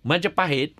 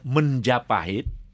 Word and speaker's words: Majapahit: Majapahit, pronounced by the author Menjapahit, sometimes the words have been stylized, you know, from Majapahit became Menjapahit, is in history, in Southeast Asian history, was the greatest kingdom, actually.